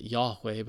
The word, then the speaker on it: Yahweh: It gets changed to Yahweh. We pronounce it Yahweh, but the Hebrew way of saying God, creator, love, sustainer.